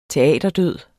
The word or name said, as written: teaterdød